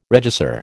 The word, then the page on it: regisseur: American English